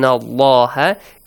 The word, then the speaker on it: الله: Pronounce the word Allah with an A sound. إِنَّ اللَّهَ كَانَ عَلَيْكُمْ رَقِيبًا.